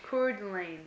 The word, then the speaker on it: Coeur d'Alene: That looks just like Alene to me. But it's Lane, so. – Coeur d'Alene, yeah.